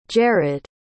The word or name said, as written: jerrad